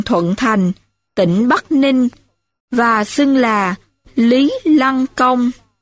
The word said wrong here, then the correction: Lý Lãng Công: xin lỗi, và xưng là Lý Lãng Công.